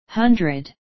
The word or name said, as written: hundred